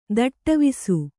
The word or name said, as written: ದಿಟ್ಟಿವಿಸ